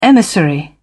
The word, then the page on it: emissary: British English